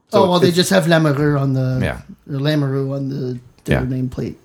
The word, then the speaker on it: Lamoureux: They pronounce it Lamoureux. – Yeah. – I was like, no, Lamoureux.